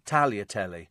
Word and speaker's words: Tagliatelle: Tagliatelle is pronounced without the G, so Tagliatelle.